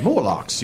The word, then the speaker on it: Morlocks: You wouldn't know that fighting morphines or dreadlocks or whatever they're called. – Morlocks, you were close. Both syllables in there, but then you had a couple extra you really didn't need.